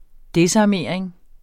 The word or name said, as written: desarmering